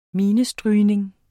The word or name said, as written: minestrygning